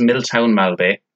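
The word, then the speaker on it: Miltown Malbay: Right, so firstly, it's pronounced Milltown Malbay, and it is in County Clare.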